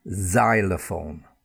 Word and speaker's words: xylophone: And then there's just the z sound, okay, and that z sound is when the x is at the beginning of the word. So let's start with that. So first pronunciation, z sound, for example, xylophone. It's spelt xy, but we've got that z, z sound, xylophone.